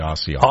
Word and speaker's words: Osceola: No, there are, and there are some people that live, that it's like, no, you're saying it wrong. It yeah. – It's actually Osceola.